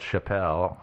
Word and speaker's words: Chappell: His name is spelled Chappelle, but it's pronounced Chappell, apparently.